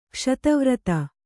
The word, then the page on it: ಖಸ್ತುವರಾತ: khastuvarāta